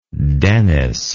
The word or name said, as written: dennis